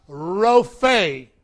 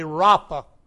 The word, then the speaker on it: Rophe: Rophe." I was taught that the two E's there, or the one E, is sounded like a double E. Some are pronouncing it like an A in our day, Rapha.